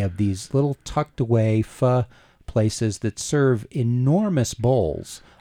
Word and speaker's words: pho: and it is pronounced pho, right? – It is. – Although spelled pho, Vietnamese rice noodles in a beef broth.